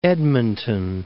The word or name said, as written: Edmonton